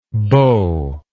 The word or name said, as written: bowlike